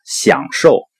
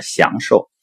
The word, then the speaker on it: 享受: 还有一个呢享受，我以前把它说成了享受，在这里提出来呢，避免误导。